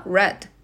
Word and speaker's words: read: まあ、これ、 read の過去形過去分詞はスペル変わんないんですけど、発音が red、赤になるっていうトリッキーさんですね。